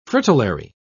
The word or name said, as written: fritillary